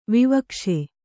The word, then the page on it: ವಿವಕ್ಷೆ: vivakṣe